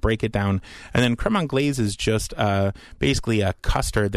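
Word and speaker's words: crème anglaise: And one more recipe you were going to share with us, macerated berries with, I never know how to say it, creme anglaise.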